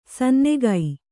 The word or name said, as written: ಸನ್ನುಗ